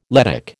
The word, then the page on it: Lettic: American English